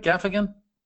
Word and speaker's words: Gaffigan: Did I say that right, Gaffigan? – You did.